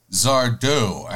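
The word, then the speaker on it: Sardo: Well, there's always Zardo, accent on the O.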